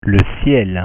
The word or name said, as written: le ciel